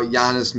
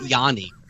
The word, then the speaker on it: Giannis: And, of course, people who don't know what we're talking about, I'm talking about Yanis Milanyanis, who is the artist on the series. – It's spelled Giannis Milanagiannis, but you say it Yanni, like the, yeah.